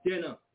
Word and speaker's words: Ganna: The celebration is called Jenna, spelled G-A-N-N-A, but pronounced Jenna.